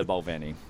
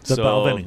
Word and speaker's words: Balvenie: And to go back to your pronunciation, it depends where you are in the country. Not even just here, in Scotland as well. I hear different pronunciations of the word. I go with what the guys say at the distillery, and they say the Balvenie.